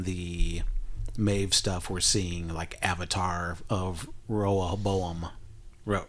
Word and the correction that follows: Rehoboam: Rehoboam. – Yeah. Rehoboam uh, mm-hmm.